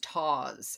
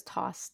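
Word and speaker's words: tawse: Pronounced taws, according to this. I think I'm making the S too soft. – Oh, see, I thought it was toss too.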